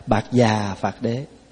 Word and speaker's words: Bạc Già Phạt Đế: Thí dụ như thay vì đọc là Pagavato, mình đọc vậy nghe khó, thì âm lại là Bạc Già Phạt Đế.